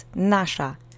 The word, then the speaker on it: nasza: The next word is nasza. We've seen this word before. It means ours. The accent is on the first syllable, and you're going to need your duck face to make the sh phoneme. I'll say it slowly. Repeat after me three times.